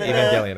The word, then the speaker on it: Evangelion: I always wondered if that was a Jellion or a Gellion, but it's an Evangelion. Okay. – That is... Cool. I feel like... In a lot of ways, Evangelion is the modern fundamental anime.